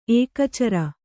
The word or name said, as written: ಏಕಚರ